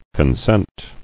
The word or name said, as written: Consenter